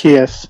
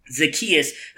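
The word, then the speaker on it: Zaccheaus: Zacchaeus. – Name. Zacchaeus, who gets his one catch for 93 yards and a touchdown.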